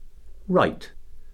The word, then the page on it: write: verb